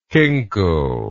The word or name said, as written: kinkle